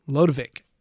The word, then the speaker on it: Lodewijk: His middle name is actually Lodewijk. I looked up the pronunciation. – I would have said Ludwig. – The W is actually pronounced more like a V, so it's Edward Ludwig Van Halen, Dutch-American.